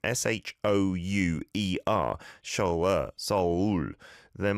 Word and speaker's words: Seoul: Miss Andriana is saying, "In the Indonesian sphere, we pronounce Seoul as S-O-U-L. Well, that'll be how it is pronounced, like the same as soul in English to mean, you know, what's inside of you that might live on after you die.